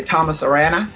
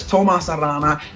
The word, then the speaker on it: Tomas Arana: And probably most Americans say Thomas Arana, but it's Tomas Arana.